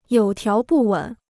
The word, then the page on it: 有条不紊: yǒu tiáo bù wěn